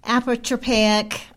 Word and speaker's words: apotropaic: And what are some examples of the apotropaic? And I'm, am I saying that right? Say it again. Apotropaic. Apotropaic. There you go.